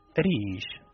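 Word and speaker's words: rish (ريش): Se pronuncia rish.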